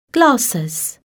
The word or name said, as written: glasses